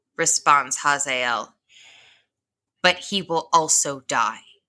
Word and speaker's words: Hazael: He sends a man named Hazael. Uh-huh. And if I say Hazel, just know I said it right this time.